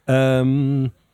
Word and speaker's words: Anyma: Enima. – Enima. A-N, because it's in capital letters, isn't it? – Yeah. – How did you pronounce it? – I said Enima, but Enima, A-N-Y-M-A.